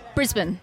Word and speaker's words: Brisbane: Brisbane.